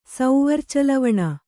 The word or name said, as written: ಸೌವರ್ಚಲವಣ